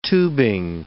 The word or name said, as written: tubing